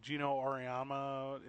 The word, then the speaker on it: Geno Auriemma: Uh, Geno Auriemma. Am I saying that correctly? – Yes, Yeah.